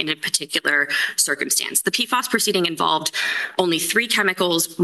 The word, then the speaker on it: PFAS: I guess it's called PFAS.